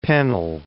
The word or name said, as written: Pennell